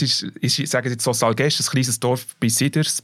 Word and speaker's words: Salgesch: Ich habe den Namen von diesem Dorf x-mal probiert zu sagen mit ihm und er hat immer wieder gesagt, nein, so spricht man es nicht aus. Salgesch? – Salgesch.